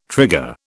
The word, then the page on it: trigger: British English